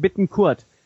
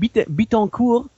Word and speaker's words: Bittencourt: Ich mein, ja, er heißt übrigens Bittencourt. Nicht wie die ganzen 82 Millionen ganzen anderen Fußballfachleute hier sagen, Bittencourt. Nein, er ist deutscher Vater oder so, und aber brasilianische Mutter. Und sofern ich weiß, spricht man in Brasilien Portugiesisch und nicht Französisch. So, ihr ganzen Klugscheißer. Er heißt Bittencourt.